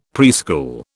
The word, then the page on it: preschool: British English